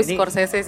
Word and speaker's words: Scorsese: ini itu Martin Sorses. – Sor hmm. bacanya apa Sorses. Sorses. nah, gue Scorsese sih gue Scorsese gue nggak tahu di Scorsese lah mm -hmm.